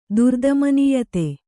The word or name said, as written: ದುರ್ದಮನೀಯತೆ